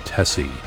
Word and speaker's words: Tecie: and a mysterious song called Tessie. It's spelled T-E-C-I-E, and it's the name of a film script he's written and an acronym for something that Moby will not reveal.